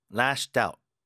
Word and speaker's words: lashed out: Lashed out, lashed out, lashed out. 뒤에 힘 들어가죠. He lashed out at his colleagues. The cat lashed out at me. Lashed out at me. Lashed out at me. At을 붙이더라도 끊기지 않게 붙여서 발음해 보세요.